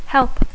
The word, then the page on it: help: English